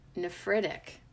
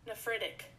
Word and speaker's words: nephritic: Today, we are gonna get na na na na na nerdy with the word nephritic. Hold up, did I say it right? – Nephritic. – Nephritic. N-E-P-H-R-I-T-I-C.